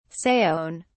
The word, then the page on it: saone: British English